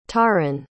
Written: taron

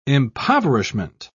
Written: impoverishment

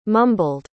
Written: mumbled

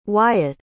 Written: wyatt

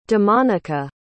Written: damonica

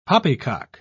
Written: poppycock

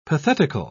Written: pathetical

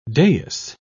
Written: dais

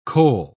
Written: kohl

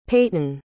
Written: peyton

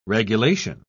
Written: regulation

